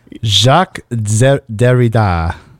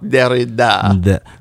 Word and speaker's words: Jacques Derrida: It's Jacques Derrida, D-E-R-R-I-D-A. How would you say that, like a French accent? – Jacques Derrida. – Jacques Derrida. – Derrida.